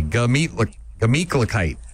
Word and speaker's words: Gemütlichkeit: Not a gluh. Gah. Just G-A. Gemütlichkeit.